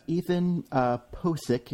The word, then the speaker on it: Pocic: Well, we got Ethan Pocic is how it's pronounced.